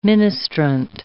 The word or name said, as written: ministrant